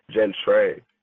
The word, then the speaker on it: Gentrey: It's pronounced Jen Trey.